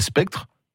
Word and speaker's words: Spectre: Alors, c'est, je ne sais pas si je prononce bien, Meltdown et Spectre. – Oui, c'est pas mal prononcé.